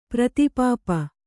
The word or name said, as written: ಪ್ರತಿಪಾಪ